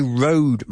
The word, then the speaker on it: rode: Yesterday I rode my bike to work. So it's rode, R-O-D-E. Yeah, it's pronounced exactly the same as our word for carretera, a road. Pero se, pronuncia, eh, se escribe de otra manera, R-O-D-E.